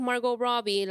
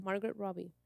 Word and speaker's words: Margot Robbie: Barbie es una persona, la Barbie que es Margot Robbie, la primera, ¿Lo dije bien su nombre? – Sí. – Margot Robbie.